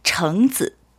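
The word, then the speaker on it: 정즈 (橙子): 오렌지는 정즈. – 정즈. – 예, 정즈. – 정즈. – 예, 즈는 뒤에 아들자자 의미가 네. 없이 그냥 들어오는 예, 전미사인 거요. 그래서 정즈 같이 하면 오렌지인데 정라는 발음은 우리 항상 사람한테 성의를 보여준다.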